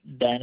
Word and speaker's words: Ben: Yeah, it's Ben Wolcott. – Can you spell that? – Ben is B-E-N, and we'll call it W-O-L-C-O-T-T.